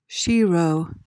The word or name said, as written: shero